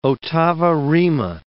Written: ottava rima

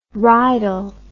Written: rydal

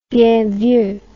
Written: bien vu

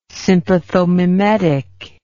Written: sympathomimetic

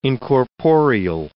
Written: incorporeal

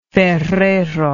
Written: ferrero

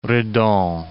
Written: Redon

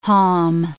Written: haulm

